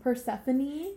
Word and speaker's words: Persephone: Persephone. – Persephone's. Oh my god. Okay. – You keep adding an E's at the end. It's Persephone.